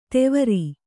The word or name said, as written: ತೆವರಿ